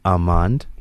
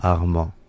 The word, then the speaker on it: Armand: Two ways of doing this. The first one is either you go Armand. – Armand. – Or you go Armand.